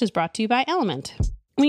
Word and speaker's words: LMNT: Enter Element, that's spelled L M N T, a tasty electrolyte mix with everything you need and nothing you don't. That means lots of salt with no sugar. Element is so sure you'll love their product,